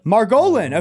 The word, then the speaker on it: Margolin: Margolin.